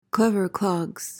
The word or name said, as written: clever clogs